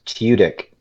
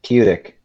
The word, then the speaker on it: Tudyk: Oh, it's pronounced Tudyk. – Tudyk? – Tudyk. T-Y-O-O-D-A-K. D-I-K. Tudyk.